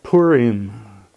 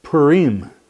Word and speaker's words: Purim: And then, finally, Esther is read on Purim, or Purim, with the accent on the second syllable.